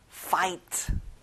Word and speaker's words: fight: 친구들, 가운데 G 발음은 안 나요. fight.